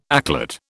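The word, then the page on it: éclat: British English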